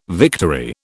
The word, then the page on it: victory: British English